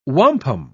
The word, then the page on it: wampum: British English